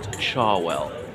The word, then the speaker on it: Cherwell: I know it's spelt Cherwell, but pronounced Charwell.